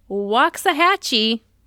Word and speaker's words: Waxahachie: It's Waxahachie, Texas.